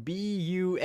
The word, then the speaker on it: Bunnicula: Right, yeah, and whereas I immediately went to the great Benicula. – Wow, that is something to behold. For the listeners, it's spelled Bunnicula.